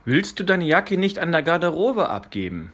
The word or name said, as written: Garderobe